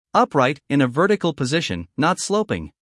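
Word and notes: upright: uk ˈʌpraɪt; en-us ˈʌpraɪt

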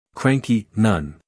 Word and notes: cranky: ˈkræŋki

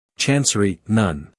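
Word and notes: chancery: uk /ˈtʃɑːnsəri/; en-us /ˈtʃænsəri/

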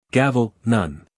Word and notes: gavel: ˈɡæv(ə)l

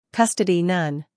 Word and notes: custody: ˈkʌstədi

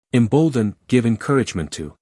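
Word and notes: embolden: uk ɪmˈbəʊldən; en-us ɪmˈboʊldən

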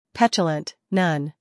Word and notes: petulant: ˈpetjulənt; ˈpetʃələnt

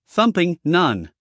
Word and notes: thumping: ˈθʌmpɪŋ

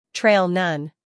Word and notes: trail: treɪl

